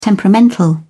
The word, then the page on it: temperamental: British English